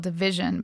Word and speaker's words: DVSN: It's a band called Division, but it's spelled D-V-S-N.